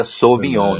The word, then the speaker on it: Sauvignon: O Sauvignon se escreve Sauvignon, mas se pronuncia Sauvignon. No francês, quando tem AU, é U.